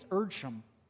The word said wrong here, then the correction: Edersheim: Edersheim, there we go. I'll go with that.